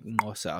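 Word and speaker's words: Xhosa: Yeah, there's a really good Trevor Noah clip for anyone that wants to see it where he explains how Kosa works, or was it like Mosa or something like that, is actually how you say it. – Mosa. Yeah. It's kind of like the way you'd call a horse.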